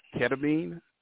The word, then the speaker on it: ketamine: if I'm pronouncing it right, ketamine. I, I mean, that's correct.